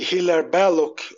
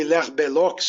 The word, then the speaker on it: Hilaire Belloc: E aí, por falar em guilda, vale lembrar que os, que os grandes distributistas da Inglaterra, como Gilbert Keith Chesterton e Hilaire Belloc, ou Hilaire Belloc, se pronunciar a moda francesa, já que ele era de origem francesa e nascido na França, né?